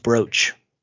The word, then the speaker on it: brooch: I think it's pronounced brooch. – Brooch. – Like you would think, it would be spelled B-R-O-A-C-H, but it's two O's.